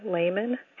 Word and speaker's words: Lehman: I come from a family that pronounced it Lehman, and that's how I say it. I've joked that I'm changing it to layperson, which I realize could be a way for people to remember that I pronounce this name Lehman. The name Barbara means stranger or foreigner,